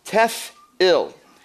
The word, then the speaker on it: tefil: The Hebrew word here is tef-il, which is spelled T-E-F-I-L, tef-il,